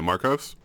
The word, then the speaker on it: Marcos: Was it Marco? – Yeah, Marcos. – Marcos, thank you. I forget. Forgetting a syllable.